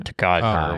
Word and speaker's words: Tokaj: I'd say Tokaji or Tokaj or whatever. – Tokaj, yeah.